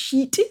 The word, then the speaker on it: shit: S-H-I-T. – Yeah, but in French it's pronounced sheet.